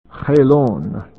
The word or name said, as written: Helon